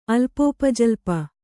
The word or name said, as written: ಅಲ್ಪೋಪಜಲ್ಪ